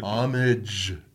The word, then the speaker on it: homage: Just so before they put the comments, you said Amish to the other homage. Yeah, yeah. I'm sorry, did I not pronounce? Did I say Amish? I think so. Yeah, homage. – Homage.